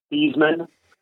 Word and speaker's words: Theismann: His name was originally pronounced Theismann, but the Notre Dame sports information director changed it to Theismann. That's a PR move. – Yeah, that's a great move, and it's Heisman for Heisman.